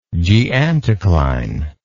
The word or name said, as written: geanticline